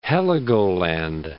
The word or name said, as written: Heligoland